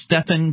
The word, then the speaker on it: Stephan: It's Stefan, but it's spelled S-T-E-P-H-A-N, Kinsella, K-I-N-S-E-L-L-A.